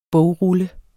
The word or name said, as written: bogrulle